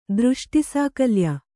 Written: ದೃಷ್ಟಿಸಾಕಲ್ಯ

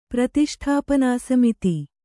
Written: ಪ್ರತಿಷ್ಠಾಪನಾಸಮಿತಿ

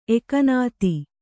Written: ಎಕ್ಕನಾದಿ

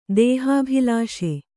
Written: ದೇಹಾಭಿಲಾಷೆ

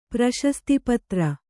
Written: ಪ್ರಶಸ್ತಿಪತ್ರ

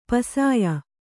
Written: ಪುಸ್ಯ

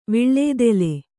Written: ವಿಳ್ಳೇದೆಲೆ

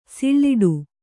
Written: ಸೊಲ್ಲಿಡು

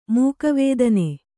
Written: ಮಗುವುತನ

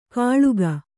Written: ಕಳುಕ್

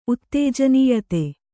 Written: ಉತ್ತೇಜನೀಯತೆ

